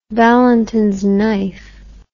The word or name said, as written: Valentin's knife